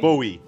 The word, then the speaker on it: Bowie: Bowie.